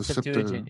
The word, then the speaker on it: Septuagint: the Septuagint, or I don't even know how to pronounce it right. – The Septuagint, yeah.